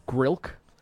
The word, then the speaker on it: Grilk: And then Grilk is the other man. – How do you spell that? – G-R-I-L-K. Kind of like milk, but with a G-R at the beginning.